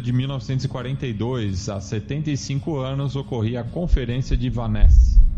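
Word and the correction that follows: Wannsee: E, não, na verdade é a conferência de Vancy. Ah. Seu alemão tá em. Você falou em holandês, é alemão.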